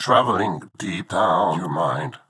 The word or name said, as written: Travelling Deep Down